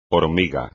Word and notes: hormiga: espagnol

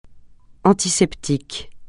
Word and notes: antiseptique: francais

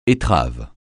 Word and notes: étrave: français

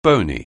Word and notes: bony: anglais

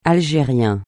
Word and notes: algérien: francais

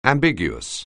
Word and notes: ambiguous: anglais

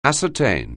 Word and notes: ascertain: anglais

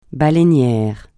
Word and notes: baleinier: francais

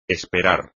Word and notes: esperar: espagnol

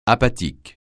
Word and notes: apathique: francais

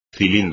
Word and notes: cilindro: espagnol

